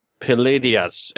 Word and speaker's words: Pleiades: Also, for the Magical Girl show, it's Wish Upon the Palladius. And Palladius is spelled Pleiades.